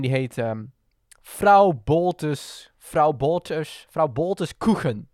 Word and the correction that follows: Kuchen: Koegen? Koegen? Zeg ik dat goed, Job? – vrouw ehm, Boltes Koegen. – Koegen. Of eigenlijk Kuchen. – Kuchen. Vrouw Boltes Kuchen.